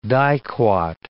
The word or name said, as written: diquat